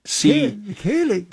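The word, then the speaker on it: céilí: Okay, and then the West Side IA Fall Sealy Set and Social Dances. Join in the fun. All are welcome. Did I pronounce that right? Sealy? The Sealy? West Side Fall Sealy? – Kaylee.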